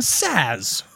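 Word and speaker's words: Saaz: Tettenang, Hallertau, Splatt, and Saz.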